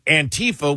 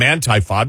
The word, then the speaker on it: Antifa: And we're like calling them anti-fa because anti-fascist. And it's like Antifa. We're like, you can't even pronounce it right.